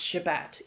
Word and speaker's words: Shabbat: For example, every Friday night into Saturday, everyone has to stop everything for the celebration of the Sabbath, or pronounced Shabbat in Hebrew.